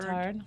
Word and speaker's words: Guittard: Well, how do you say the one? Is it Guittard? – Guittard.